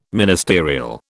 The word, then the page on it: ministerial: British English